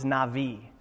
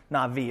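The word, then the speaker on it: Navi (נביא): And so the word, we look at the word itself, Hebrew, the Hebrew word for prophet is Navi. Navi, it's pronounced with a V, even though it's spelled with a B in our language.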